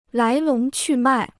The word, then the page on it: 来龙去脉: lái lóng qù mài